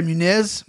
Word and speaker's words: Muniz: How do you say his name? – Andre Muniz. Muniz. Yeah.